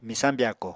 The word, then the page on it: Misañ byako: Apatani